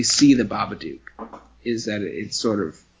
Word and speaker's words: Babadook: Some people will tell you it's pronounced Babadook, and they are wrong. The movie will tell you that it's pronounced Babadook, and I disagree with that. I know that every other word that's spelled O-O-K makes an ook sound, but listen. Anyway, I think it was a very good...